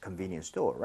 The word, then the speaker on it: convenience store: 발음을 할때 T 사운드가 거의 안 나거든요. 그래서 얼핏 들으면 두개 차이를 알 수는 없습니다. 그래서 우리가 오히려 미리 이 단어를 알아두고 그다음에 적용을 해야겠죠. Convenience store, right? – 네.